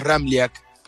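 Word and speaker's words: Ramljak: Es un poco complicado porque tiene tres consonantes juntas y la J se pronuncia como una I. Bien pronunciado, el apellido es Ramliak.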